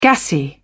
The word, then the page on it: gassy: British English